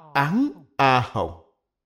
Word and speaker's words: Án A Hồng: Đều là một ý nghĩa, đọc là Án A Hồng.